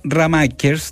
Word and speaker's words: Ramakers: ¿Estaba bien pronunciado el apellido? – Sí, Ramaker.